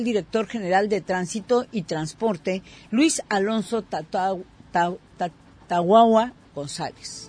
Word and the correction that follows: Tahuahua: Y corrijo y pronuncio bien su nombre, es Luis Alonso Tahuahua González.